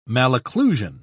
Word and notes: malocclusion: British English

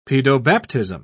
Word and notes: pedobaptism: British English

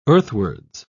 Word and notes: earthwards: British English

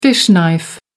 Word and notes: fish knife: American English

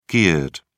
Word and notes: geared: British English

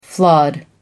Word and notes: flawed: American English

